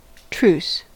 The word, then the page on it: truce: American English